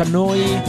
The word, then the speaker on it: Neu: Si scrive Neu, si pronuncia Noi, Noi.